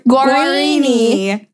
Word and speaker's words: Guarini: Justin Guarini. Is that how you say it? – Guarini.